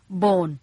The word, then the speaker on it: Bohn: Bone B-O-H-N Bone ซึ่งออกเสียงเหมือนกับ B-O-N-E Bone ที่แปลว่ากระดูก